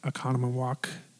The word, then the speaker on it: Oconomowoc: She serves proudly on the faculty of Hava Nashira in Oconomowoc, Wisconsin.